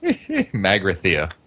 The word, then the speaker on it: Magrathea: Magrathea.